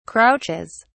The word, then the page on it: crouches: British English